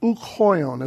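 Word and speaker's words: ouk hoion (οὐχ οἷον): Notice the rough breathing. Hoion. And since ouk does not have an accent, it only has a breathing mark, therefore ouk is pronounced with hoion. It's ouk hoion, as if they're one word.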